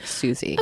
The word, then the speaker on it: Suze: Well, it's spelled Suze, but I believe it's pronounced Susie.